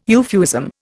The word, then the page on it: euphuism: Indian